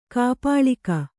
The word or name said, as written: ಕಾಪಾಳಿಕ